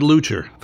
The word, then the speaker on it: Leuchter: i am going to mispronounce his name. Fred Luchter? – Fred Leuchter.